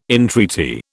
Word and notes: entreaty: British English

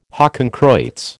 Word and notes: hakenkreuz: American English

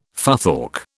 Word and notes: futhorc: British English

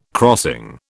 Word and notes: crossing: British English

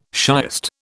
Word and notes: shiest: British English

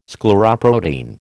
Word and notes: scleroprotein: American English

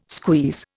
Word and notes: squeeze: Indian

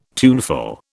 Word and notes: tuneful: British English

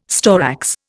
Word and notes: storax: Indian

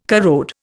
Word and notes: corrode: Indian

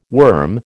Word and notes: worm: American English

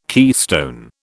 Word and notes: keystone: British English

